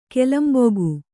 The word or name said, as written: ಕೋಲಂಬಕ